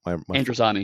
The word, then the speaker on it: Androzani: I've lost my Androzani. – My flow. Thank you. Androzani, Major, a robber baron who demands to see the prisoners.